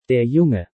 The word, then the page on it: Der Junge: German